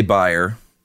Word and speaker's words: Bayer: If you heard me say buyer and you're thinking, dude, it's Bayer aspirin. What are you, German? No, I'm not German, but that's right. How you pronounce it over there. It's Bayer. We pronounce it Bayer over here.